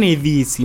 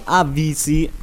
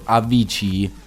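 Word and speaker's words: Avicii: Allora, DJ, ascolta, non Evisi, ma bensì Avisi. – Oh, lo volete capire? – Lo volete capire? – Ma sai, dipende un po' dalle pronunce e dal luogo dove si pronunciano, perché per esempio a Tomorrowland lo hanno pronunciato Avici, quindi è molto diverso. – Capito? – Vabbè, comunque si dice Avisi, punto.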